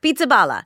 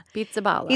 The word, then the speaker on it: Pizzaballa: Because I'm like, that couldn't possibly be how the name is pronounced, despite Pizzaballa? – How phonetic it looks. Pizzaballa. – Pizzaballa.